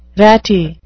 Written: ratty

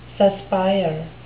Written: suspire

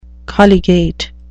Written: colligate

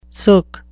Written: sook

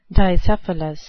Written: dicephalous

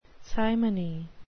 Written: simony

